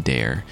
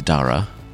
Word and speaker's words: Dara: The man I was calling Dare is Dara.